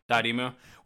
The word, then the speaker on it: Dotemu: Dotemu.